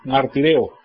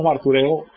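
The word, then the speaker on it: martureo (μαρτυρέω): A veces en las traducciones modernas la palabra u la traduciones como i, martireo, ¿verdad? Pero nosotros lo llamamos martureo, se entiende de todas maneras. Martureo tiene el acento en la e.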